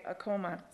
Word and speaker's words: Acoma: The real question is, how do you pronounce Acoma or Acoma? Acoma is the tribal name, but we, I think. – Yeah, that's awesome. – We say Acoma.